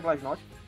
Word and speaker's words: Glasnost: O Glasnost, Glasnow, Glasnost, já vi muita gente pronunciando de forma diferente, eu vou pronunciar Glasnost.